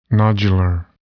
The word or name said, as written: nodular